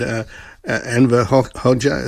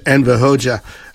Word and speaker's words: Enver Hoxha: Well, given it's the political DNA coming out of the paranoia of the Cold War era of this weird Enver Hoxha, is that how you pronounce it? – Gotcha, yeah. – Enver Hoxha, the little wonder that the politics have not really evolved.